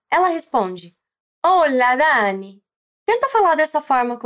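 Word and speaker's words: hola: A palavra que utilizam é o clássico olá. Aqui eu quero te chamar a atenção para a diferença de pronúncia. Em português, a gente enfatiza o lá, olá, mas em espanhol a ênfase está no o.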